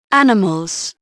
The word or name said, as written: animals